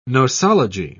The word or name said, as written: nosology